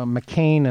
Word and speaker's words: McCain: Uh, the law firm of uh, McCain and, uh, which, by the way, rhymes with Cain.